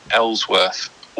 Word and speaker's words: Eelsworth: It's actually Shirley Eelsworth, although it is spelt Eelsworth, so that's a common mistake. – Eelsworth. – Double E. – Double E Eelsworth.